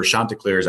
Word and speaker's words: Chanticleers: That was Liberty in the big showdown with the Chanticleers, or Chanticleers, I believe is the proper pronunciation, Coastal Carolina.